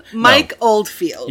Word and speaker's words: Mike Oldfield: Sorry, when you run it together, it sounds like you're saying Mike Goldfield. – My Goldfield. Leprechaun 14. My Goldfield. – Mike Oldfield. – Yes, Mike Oldfield.